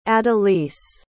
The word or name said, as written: adelise